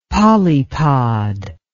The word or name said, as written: polypod